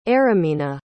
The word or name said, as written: Aramina